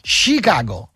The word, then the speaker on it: Chicago: Io ti stimo e non voglio fare il professorino di turno, ma si pronuncia Chicago.